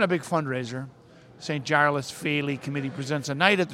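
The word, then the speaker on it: Féile: How do you say that, Bernie? The field? The field? The field. That's a GAA thing. The Faley. She don't know. You'll be with us in just a minute. St. Giles Youth GAA is proud to be sending its first team from Chicago to participate in the Faley tournament. Pronounced Faley. She's right, Faley.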